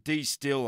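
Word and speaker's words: De Stijl: I'm pretty sure that's how you pronounce it, D-Still. I've never really heard of anybody pronouncing it properly. But it's spelled D-E and then S-T-I-J-L, so D-Still, I guess.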